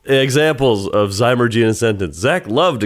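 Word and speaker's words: zymurgy: I'm going to click to play it so we can get this, how it's pronounced. – Zymergy. – Zymergy.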